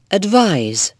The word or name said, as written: advise